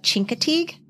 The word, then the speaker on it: Chincoteague: So some of the other animal stories our society members shared were Wind in the Willows, Trumpet of the Swan, Misty of Chincoteague. Did I say that right? – You did. Chinky-tink.